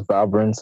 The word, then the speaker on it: Valbrun: Yeah, it's Micheland the last name is Valbrun.